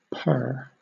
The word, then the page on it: purr: British English